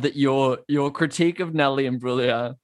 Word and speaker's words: Imbruglia: Is it Imbruglia or Imbruglia? – I think the G is silent. I think it's just Imbruglia.